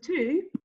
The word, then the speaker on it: two: Because two, T-W-O, T-O-O, T-O. Where does T-W-O come from? Like, why is a silent W make two? – I have no clue.